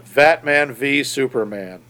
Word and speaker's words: Batman v Superman: Not even VS as versus, but Batman v Superman.